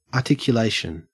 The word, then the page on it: articulation: Australian English